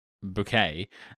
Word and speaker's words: Bucket: And she pronounces it Bouquet, and it's like written Bucket.